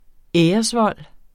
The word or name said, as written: æresvold